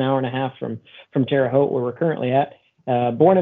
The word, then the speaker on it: Terre Haute: Our special guest today is operator of Terre Haute FSR in Terre Haute, Indiana.